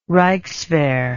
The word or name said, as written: reichswehr